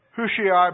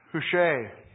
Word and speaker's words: Hushai: David sent his friend Hushai back to Absalom. Hushai is actually how it's pronounced, I'm sorry.